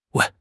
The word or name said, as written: 喂